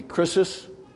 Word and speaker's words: krisis: The Greek word would be like, almost, you could almost pronounce it like crisis, but it'd be krisis.